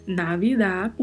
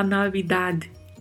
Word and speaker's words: Navidad: Navidad, o D é um D, como diríamos no português, né, um D mudo. Ele não tem, na verdade, uma vogal na sequência, então não podemos falar Navidade, né, Navidade, né, não. É Navidade, o D ele meio que se perde na pronúncia, né? Navidad.